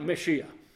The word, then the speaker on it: Mashiach: And in the Hebrew, they might pronounce it something like Mashiach.